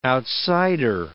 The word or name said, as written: outsider